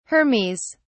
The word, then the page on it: hermes: British English